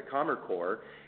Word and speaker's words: Kammerchor: the Kammerchor. And Jackie, you did a very good job of pronouncing that, by the way.